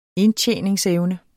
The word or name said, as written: indtjeningsevne